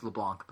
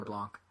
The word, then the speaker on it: LeBlanc: It's LeBlanc, by the way. Whatever. LeBlanc. I mean, it's spelled LeBlanc.